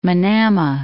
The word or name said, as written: Manama